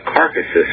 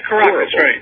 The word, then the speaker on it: Caracas: Venezuela, it's a carcasses or something. It almost rhymes with you're a dead body. – Yeah, Caracas, Caracas.